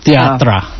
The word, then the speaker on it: théâtre: Actually, it's the French pronunciation théâtre. – Theatra.